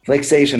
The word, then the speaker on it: Flixation: Did I pronounce that right, Flixation? – Flixation, that's the name of the company.